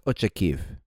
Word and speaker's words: Ochakiv: These were gifts from his father from the Siege of Ochakov, which is located in modern-day Ukraine and pronounced Ochakiv.